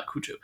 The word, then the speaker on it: Cujo: Euh, Cujo, Kuro, je sais pas comment il faut prononcer. Je pense qu'on dit Kuro, mais. Euh, cela dit, dans les podcasts américains que j'ai écoutés, euh, tout le monde dit euh, Kojo. – Kojo, on dira Kojo.